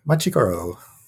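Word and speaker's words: Machi Koro: Is it pronounced Machikoro? I believe that's what it says in front of me. – Machikoro.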